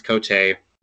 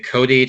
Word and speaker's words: Cote: It's French Canadian. Initially, it was pronounced Cote. When whichever relative moved to New England in the 20th century, made it Cody to Americanize it.